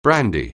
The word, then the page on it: brandy: anglais